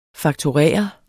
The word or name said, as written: fakturere